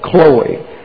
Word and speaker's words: Chloe: An omega is a long O, Chloe. But it's a short O, accented there.